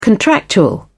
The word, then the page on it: contractual: British English